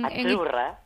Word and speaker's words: Azzurra: Azzurra. – Azzurra. Mira, ¿ves? – Azzurra. Es que... Sí, como porque pizza, la Z no es la Z como decimos en catalán tan sonora, no. Ah, es azzurra.